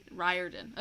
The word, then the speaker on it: Riordan: Riordan.